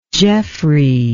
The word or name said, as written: geoffrey